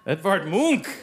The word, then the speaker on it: Edvard Munch: Edvard Munch!